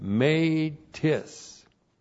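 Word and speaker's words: made tis (μήτις): And then we have made tis. M long E-T-I-S, made tis.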